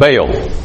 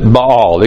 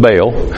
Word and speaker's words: Baal: Now, another thing you need to know about Baal. Some pronounce it Baal. It could go either way. We'll stick with Baal.